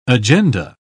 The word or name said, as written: agenda